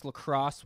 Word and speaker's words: La Crosse: So La Crosse, this is La Croix, because that's where the La Crosse and St. Croix rivers meet.